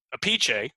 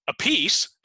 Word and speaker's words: Appice: Carmine pronounces it Appice, so we were right on that. And Vinnie pronounces it Appice, and she summed it up with, nobody knows why, not even them.